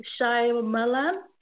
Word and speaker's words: Shyamalan: Let me go on Google and see if I can actually pronounce it correctly. M. Night. Yes, yeah, so it's Shyamalan.